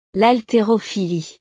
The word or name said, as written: l'haltérophilie